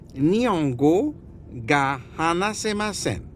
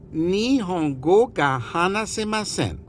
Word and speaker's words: Nihongo ga hanasemasen: Hagamabaga. – Nihongo ga hanasemasen. Und immer an die Aussprache denken. Immer als wenn du lächelst. Von einem Ohr zum anderen. Und alles zwei Oktaven höher.